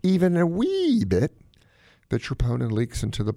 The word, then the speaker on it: troponin: And it's called a troponin level, T-R-O-P-O-N-I-N. The English pronounce it troponin.